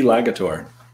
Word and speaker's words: Lagator: L-A-G-A-T-O-R. How do I pronounce that? Legator? – C.C. Legator. – C.C. Legator. So C.C. Legator. I like it. It's like C.C. Yeah. – Legator is Montenegrin.